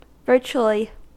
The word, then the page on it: virtually: American English